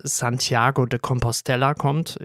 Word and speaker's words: Compostela: Ich habe es auch extra nachgeguckt, es wird wirklich Compostela ausgesprochen. Irgendwie begegnet einem häufiger auch, dass es Compostela ausgesprochen wird, was aber, glaube ich, nach meinen sehr einfachen spanischen Kenntnissen gar nicht so sein könnte, weil sonst müsste es ja, glaube ich, mit 2L geschrieben werden, wird aber mit einem L geschrieben.